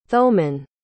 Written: thoman

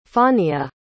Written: faunia

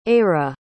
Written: eira

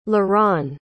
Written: laran

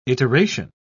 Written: iteration

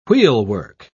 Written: wheelwork

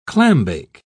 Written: clambake

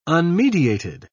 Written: unmediated

